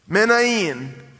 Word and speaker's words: Manaen: Then you have this man named, I'm going to make sure I say his name right, Manaen. It's tough to say. You try. Manaen.